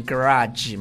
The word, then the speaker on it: garage: 但超说那后面可以读成 garage 吗？ – 不可以，不是 zh，它是 garage，反正是 garage。